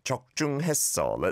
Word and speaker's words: 적중했어: Good pronunciation, really good. 적중했어.